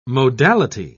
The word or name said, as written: modality